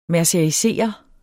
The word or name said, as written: mercerisere